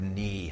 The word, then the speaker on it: knee: So she was the first one to teach me that the word knee had a silent K in it.